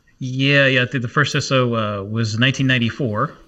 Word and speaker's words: CISO: I think at least that's how I pronounce it. CISO.